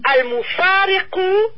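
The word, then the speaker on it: al-mufariqu: C'est ça que j'allais vous demander exactement, le Q et le U, ka. – Oui, c'est un kaf et le U se prononce toujours U. Al-mufariku. – Al-mufariku, ok.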